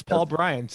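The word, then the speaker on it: Paul Brian: I think we've established the P is silent for our purposes, but it is definitely necessary for writing. Is that accurate? – Yeah. So it's Paul Bryan.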